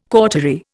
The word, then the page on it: cautery: Indian